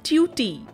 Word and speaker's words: tutee: In this episode of chalk and duster the word of the day is ट्यूटी।